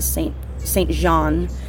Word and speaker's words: Saint Jean: So it's probably is Saint Jean. – Yeah. – But it's spelled St. Jean, like J-E-A-N.